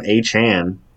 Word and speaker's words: Achane: Devon Achane, which he prefers his name to be pronounced like that apparently.